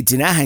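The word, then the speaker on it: Tinaja: I mean, it's T-I-N-A-J-A, Tanaja in Spanish. It's like a type of jar where you collect water.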